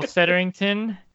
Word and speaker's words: Setterington: Michael Setterington.